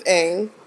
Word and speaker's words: Aang: That's not how his There's no one on their set. There was not a script supervisor. There wasn't anyone on that set that was like, actually, it's Aang.